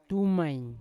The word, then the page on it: tumain: Yawa